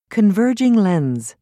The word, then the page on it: converging lens: British English